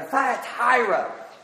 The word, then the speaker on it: Thyatira: The Smyrna or Laodicea or Thyatira. Maybe you've always wondered how to pronounce Thyatira. Well, that's it.